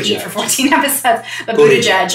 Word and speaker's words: Buttigieg: Buttigieg. I can actually say his name. – Buttigieg. – Buttigieg.